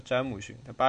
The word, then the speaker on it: 詹姆逊: 看这个标准发音叫詹姆逊，他八十年代的时候叫詹明信，我我我我教惯了，都可以啊，无所谓。